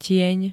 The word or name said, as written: tieň